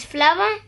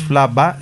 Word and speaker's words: flava: Lo único, en Esperanto las uves son labiodentales. Entonces tienes que morderte, tienes que tocarte un poco el labio. No es flava, sino flava, va.